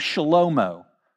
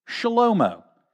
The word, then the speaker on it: Shlomo: In the Hebrew, you would pronounce the name Shlomo. Shlomo.